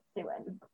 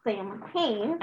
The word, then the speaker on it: Samhain: Though you're probably, if you look at it, tempted to pronounce it Samhain. – I used to pronounce it that way. – It's actually pronounced Samhain or Samhain.